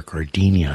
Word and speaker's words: Gardenia: Bill Withers before that with Lovely Day, Iggy Pop as well, Gardenia, and or Gardenia, I guess, is the way it's typically pronounced.